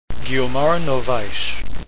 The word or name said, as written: novaes